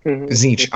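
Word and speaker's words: Tzeentch: Zeench. I like Zeench. I'm glad that you say Zeench too. – All right. – So Zeench, and we have this discussion because it's literally spelled like T-Z-E-E-N-T-C-H. T-C-H.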